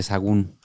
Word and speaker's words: Sahagún: De hecho, Fray Bernardino de Sahagún, que no sé si lo dije bien. – Mm. Fray Bernardino de Sahagún.